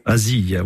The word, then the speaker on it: Azille: arrivé à, dans l'Aude, à Asile, c'est bien comme ça qu'on prononce, hein, je crois, le, village. – Asile, voilà.